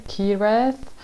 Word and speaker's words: Kiraeth: Eccoci, quindi l'avete sentito, questo era David Kireth, si scrive se lo volete cercare eh, David K-I-R-A-E-T-H.